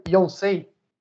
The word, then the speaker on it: Yonsei: Eu acho que de onde você já está fazendo mestrado, que é a Universidade de, falei certo, Yonsei? – Isso.